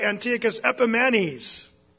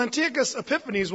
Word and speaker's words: Antiochus Epiphanes: The Jews, they used to say his name wrong purposefully. They'd say Antiochus Epimanes. Why would they say that? Well, Antiochus Epiphanes was a madman.